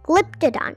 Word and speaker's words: Glyptodon: Glyptodon, glyptodon. You say it like this Glyptodon.